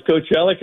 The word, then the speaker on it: Coachella: and then Coachella. Is that how you say it? Coachella? Is that? – That's exactly right. Coachella, California.